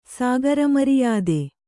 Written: ಸಾಗರಮರಿಯಾದೆ

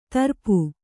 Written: ತುರಿಪ

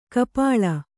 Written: ಕಪೋಳಿ

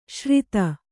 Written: ಶ್ರಿತ

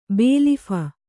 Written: ಬೇಲೀಫ

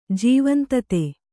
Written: ಜೀವಂತತೆ

